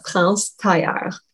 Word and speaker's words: France Tailleur: It is France Tailleur.